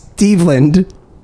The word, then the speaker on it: Steveland: His name is Steveland. Not Steven. Not Stefan. It's Steveland. Like rhymes with Cleveland.